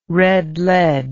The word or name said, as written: red lead